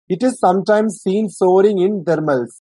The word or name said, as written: soaring